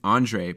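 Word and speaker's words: Ondřej: Okay, well, for everyone out there, it's Andrej Palat, and the J is silent.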